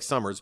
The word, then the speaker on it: Somers: It looks like Somers, but it's pronounced Somers.